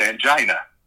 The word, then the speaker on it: angina: And the medical director on the set kept telling me, it's angina. And I said, well, I've never heard it pronounced angina. I've always heard it pronounced angina. No, it's angina, angina.